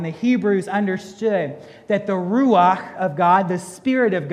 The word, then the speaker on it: ruach: This is how you say ruach. There's got to be something coming out of your mouth. You're not saying it right. Ruach. Ruach. There's got to be a breath behind that word, because again, that word means the breath of God.